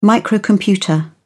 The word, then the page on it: microcomputer: British English